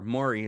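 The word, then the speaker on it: Mowry: I think one of the Maori sisters, Tia or Tamara, or Maury is how you pronounce it, were part of this, if I recall correctly.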